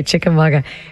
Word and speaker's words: Chickamauga: Did I say that right? – Chickamauga. – Yeah. – It's a Cherokee word. – Okay, Chickamauga.